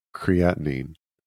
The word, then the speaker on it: creatinine: creatinine did i say that right